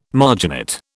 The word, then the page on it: marginate: British English